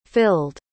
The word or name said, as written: filled